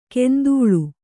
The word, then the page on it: ಕೆಂದೂಳು: kendūḷu